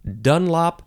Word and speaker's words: Dunlop: And you can emphasize either syllable. It has a capital D, and it is spelled D-U-N-L-O-P. Dunlop or Dunlop.